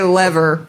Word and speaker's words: lever: It says L-E-H-V-R. – Lever. – Lever. – Lever. – They're telling you that it's le and not lee, you dumb dumb.